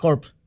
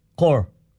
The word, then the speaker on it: corps: At Actually, ang pag-pronounce niya is not corpse. Core.